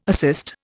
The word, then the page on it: assist: Indian